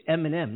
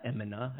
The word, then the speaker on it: Eminem: It's Eminem, not Emina. I got it wrong here.